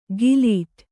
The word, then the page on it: ಗಿಲೀಟ್: gilīṭ